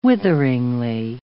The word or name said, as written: witheringly